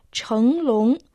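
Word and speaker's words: 成龙: Да, китайское имя Джеки Чана состоит из двух иероглифов «чхэнг лонг». Первый иероглиф «чхэнг» произносится во втором доне, имеет значение «стать», а второй – лонг произносится дальше во втором доне, означает дракон.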